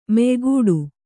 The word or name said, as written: ಮೆಯ್ಗಿಡು